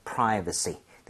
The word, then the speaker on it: privacy: 그래서 privacy. 대신에 발음할 때 V가 나왔습니다. – 네. – Private가 아니라 입술이 붙으면 안 되죠. 자, V는 F와 비슷하게 입술이 붙으면 안 돼요.